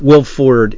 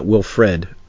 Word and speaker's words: Wilford: Will Ford. It's Will Ford Brimley, not Wilfred.